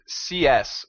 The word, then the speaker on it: CIESS: It's spelled C-I-E-S-S. – It's actually pronounced C-S, which is hilarious because, I mean, C-S is the acronym for computer science.